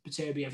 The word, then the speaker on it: Beterbiev: is that how you pronounce it? – Beterbiev. – That's it. Beterbiev, yeah. I want to see that fight badly.